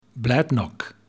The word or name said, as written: Bladnoch